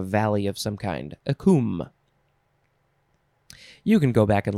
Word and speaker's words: cwm: It's pronounced cum, C-W-M, noun from 1853.